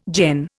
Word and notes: gen: Indian

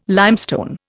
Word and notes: limestone: Indian

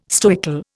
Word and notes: stoical: Indian

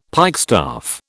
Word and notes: pikestaff: British English

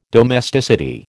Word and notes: domesticity: American English

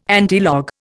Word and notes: antilog: Indian

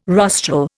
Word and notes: rostral: Indian

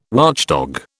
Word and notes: watchdog: British English

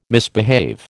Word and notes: misbehave: American English

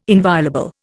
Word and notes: inviolable: Indian

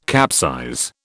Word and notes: capsize: British English

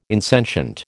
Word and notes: insentient: American English

